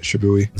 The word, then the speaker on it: Shibui: So her favorite track is Shibui. Is that how you say that word? – Yeah, I would agree that's how you say it, Shibui.